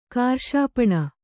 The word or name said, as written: ಕಾರ್ಷಾಪಣ